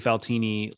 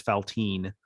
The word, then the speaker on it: Faltini: Yeah, I definitely mispronounced Trey Faltini like at least halfway into his first season as Faltine.